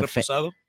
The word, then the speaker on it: Reposado: They do a vodka, a Raspado. I don't know. I forget what that is, but I think it's a Reposado. – Yeah. How do you say it? – Reposado.